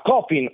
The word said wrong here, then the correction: Chopin: o Chopin, che poi dopo si voglia dire con accento francese, ma a Schubert.